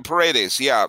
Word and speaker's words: Paredes: Isaac Paredes, is that how you pronounce his name? – Paredes.